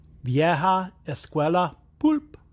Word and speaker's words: Vieja Escuela Pulp: Vieja Escuela Pulp.